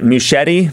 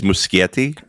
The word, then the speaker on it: Muschietti: I think it's Muschietti. – Muschietti. – I mean, so. I would assume that it was based on you know, Italian pronunciation, but I'm pretty sure I've heard them say Muschietti in videos.